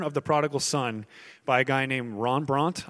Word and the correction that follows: Rembrandt: I can't say his name right. It's Russian. Rembrandt. Rembrandt. Rembrandt.